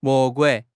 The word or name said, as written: 魔鬼